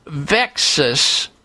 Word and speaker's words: VEXAS: VEXAS, V-E-X-A-S, sort of rhymes with Texas, VEXAS syndrome.